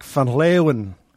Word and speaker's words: Van Leeuwen: Did I say it right? – That's perfect for an American pronunciation. – Close, yeah. – Is it Leeuwen? – It's more like Van Leeuwen.